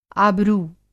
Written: आबरू

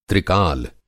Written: त्रिकाल

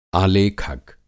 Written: आलेखक

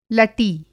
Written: लटी

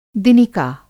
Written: दिनिका

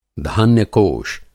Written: धान्य-कोष

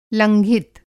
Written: लंघित

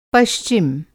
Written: पश्चिम

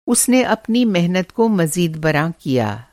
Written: मज़ीदबरॉ

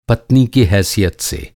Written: हैसियत

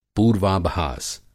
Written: पूर्वाभास

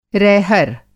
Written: रैहर

